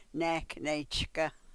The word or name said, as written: nek ne-chkah